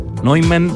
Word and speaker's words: Neumann: Tuve que buscar bien la pronunciación porque yo pensé que era John von Neumann, pero no, es Neumann. Bueno,